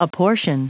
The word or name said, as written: apportion